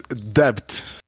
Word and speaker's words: debt: D-E-B-T. – Yeah. – And for a long time, I thought it's debt. And no, it's not. It's debt. Debt, like debt, fact. So, okay, they don't pronounce some letters.